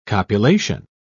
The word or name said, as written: copulation